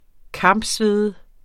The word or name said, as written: kampsvede